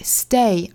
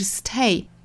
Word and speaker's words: stay: 还要注意最后的 stay T 要注意发成一个不送气的的的状态， stay 而不是 stay.